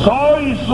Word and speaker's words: sois: Seis, unos mentirosos, chico. – Sois unos mentirosos. – Mira, te lo está diciendo este, que es que sabe. – Que no se dice seis. Se dice sois.